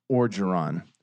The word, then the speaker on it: Orgeron: We Tigers, Coach Ed Orgeron. Chicken on a stick. Look, tough breaks for them. They had some drops down the stretch. Nope. – Can we just say this right now? It is fucking Orgeron. – Yeah. – O-R-G-E-R-O-N. O-R-G-E-R-O-N.